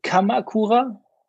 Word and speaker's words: Kamakura: So I had a local karate dojo in Karakuma, and went there for the time being in the temple. – And the first week that you lived in how do you say it correctly, Kamakura.